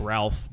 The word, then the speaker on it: Ralph: I don't know why it's pronounced Ray. It's like Ralph, but it's Ralph Fiennes. – Yeah. Anyways, Ralph, I don't feel like the LPH is silent.